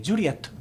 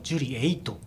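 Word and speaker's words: Juliett: 例えば J ですよね。ジュリエットですけども、ジュリエイトって発音してる、これ多いですよね。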